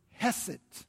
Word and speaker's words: chesed: The word is hesed. Now, in some of your dictionaries, it's going to show up C-H-E-S-E-D, but the C is silent. Hesed.